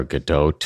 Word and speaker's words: Gadot: I think it's like Godot or Godot. I think you pronounce the T.